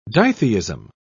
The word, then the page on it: ditheism: British English